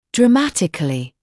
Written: dramatically

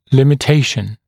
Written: limitation

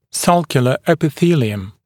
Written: sulcular epithelium

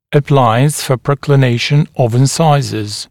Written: appliance for proclination of incisors

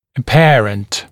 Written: apparent

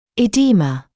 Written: edema

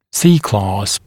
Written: C-clasp

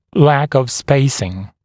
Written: lack of spacing

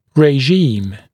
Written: regime

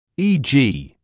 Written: e.g.

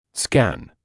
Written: scan

